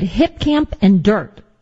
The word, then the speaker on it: Dyrt: And then I've also used the Dirt app. It's spelled D-Y-R-T, mm-hmm.